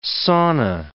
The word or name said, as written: sauna